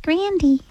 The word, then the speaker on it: Grande: It's actually pronounced Grandy.